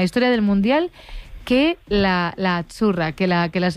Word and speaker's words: azzurra: Azzurra. Sí, como porque pizza, la Z no es la Z como decimos en catalán tan sonora, no. Ah, es azzurra. – Muy bonito, muy bonito. – Es como pizza.